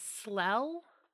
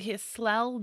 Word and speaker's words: Clell: Slell, I think is how you'd pronounce it. – Sorry. – Slell Miller, I think, is what it's going to be. – Oh. – C-L-E-L-L.